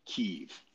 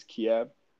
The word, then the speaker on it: Kyiv: Okay, we're 20 miles away from Kyiv, and I am going to pronounce it that way. I recently found out that's the Ukrainian way of pronouncing it. The Russian way is Kiev, so it actually pisses Russians off if you call it Kyiv.